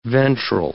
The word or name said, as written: ventral